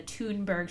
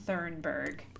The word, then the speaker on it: Thunberg: And Joanna rang in, but unfortunately mispronounced the name Greta Thunberg. She said Thurnberg, so added an R where there isn't an R.